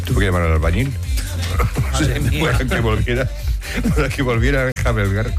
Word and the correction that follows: enjalbegar: ¿cómo se dice? – Enjalbegar la pared. – O enjabelgar. – Enjalbegar.